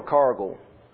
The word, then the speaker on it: Cargill: Is it Cargill or Cargill? Cargill. The G is silent, I believe.